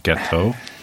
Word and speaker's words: gâteau: 50th Celebration Chocolate Gâteau.